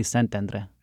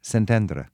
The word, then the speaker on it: Szentendre: How do you say it in Hungarian? – In Hungarian, that would be Szentendre. – Szentendre.